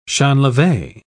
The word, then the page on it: champleve: British English